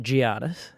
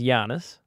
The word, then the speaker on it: Giannis: Well, you don't pronounce it Giannis. – What is it? – It's Giannis. – Giannis. – Giannis. Giannis. Like, imagine there's a Y there.